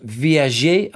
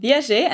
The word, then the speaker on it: viager: It's called viager. I think I've pronounced that rightly. You can correct me if I haven't. I know very little about it, but thankfully, you do. Just explain how this works. – Yeah, well, you pronounced it just right.